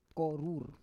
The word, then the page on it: korur: Roon